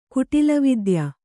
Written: ಕುಟಿಲವಿದ್ಯ

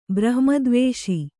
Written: ಬ್ರಹ್ಮದ್ವೇಷ

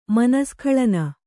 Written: ಮನಸ್ಖಳನ

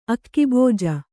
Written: ಆಖುಭುಜ

